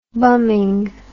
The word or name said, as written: bumming